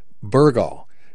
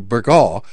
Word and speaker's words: Burgaw: Natives call the town Burgaw, with the first syllable stressed, as opposed to Burgaw, which stresses the second syllable.